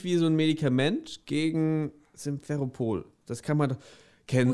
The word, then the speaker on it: Simferopol: Wie schreibt man das? S-I-M? – Äh, so wie Sims? S-I-M. S-I-M-F-E-R-O-P-O-L. Simferopol.